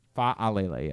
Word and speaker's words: Fa'alele: Fa'alele, yes. – Fa'alele. – Yeah. – Okay. – I mean, as long as you make it clear, there's an extra syllable there.